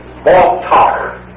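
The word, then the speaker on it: baltach (בָּטַח): In the Old Testament, the term trust is pronounced baltach. Baltach. B-A-T-A-C-H. The C-H is always a sound on the end of it.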